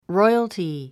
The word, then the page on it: royalty: rɔ́iəlti; ロイアルティ